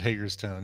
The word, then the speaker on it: Hagerstown: Is it Hagerstown? – I would pronounce it Hagerstown. Yeah. – Maybe it's Hagarstown.